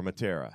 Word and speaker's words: Matera: Or Matera. How do you pronounce that? – Matera. – Is the M silent? Is it just Atera? – Nope. It's okay.